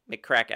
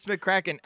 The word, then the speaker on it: McCracken: M-C Mick... C-R-A-C-K-E-N, McCracken. I can't think of any other way of pronouncing. McCracken, maybe? – Oh, no, it's McCracken.